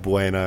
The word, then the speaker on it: Buena: Natives call it Buena. – I've always said Buena. I've lived here my whole life. – Because that's the appropriate Spanish.